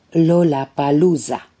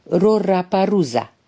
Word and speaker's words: Lollapalooza: În limba japoneză însă nu există sunetul L, Japonezul care se declara filipinez ca să poată spiona la americani pronunța greșit Roraparuza.